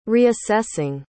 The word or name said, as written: reassessing